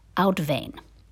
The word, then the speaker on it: uitwaaien: The word is spelled uitwaaien, and it's pronounced uitwaaien.